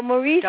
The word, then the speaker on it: Mauricio: Oh, Mauricio.